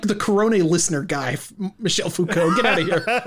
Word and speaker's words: Foucault: How do you pronounce it again? Foucault? – Foucault, but...